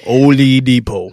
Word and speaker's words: Oladipo: Victor Oladipo. – She said like she pronounced like every syllable Oladipo.